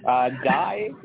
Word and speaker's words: Dai: Dai is a Welsh name for David, and it's spelled D-A-I.